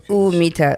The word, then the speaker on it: humita: So humita. So the H is silent. – The H is silent. – Yeah, so it's humita. – Okay, humita. – Humita, perfecto.